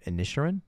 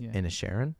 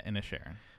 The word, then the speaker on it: Inisherin: But I watched The Banshees of Inisherin. – Yes. – Did I say it right? – Yes. – That was a joke in the Oscars. Like people keep saying Inisherin, but it's Inisherin. – Yeah, Inisherin.